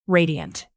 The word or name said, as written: radiant